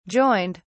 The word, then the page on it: joined: British English